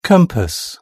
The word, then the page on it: compass: British English